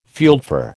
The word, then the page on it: fieldfare: American English